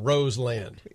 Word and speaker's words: Roseland: This is spelled Roseland. – Yeah. – That's not Roslyn.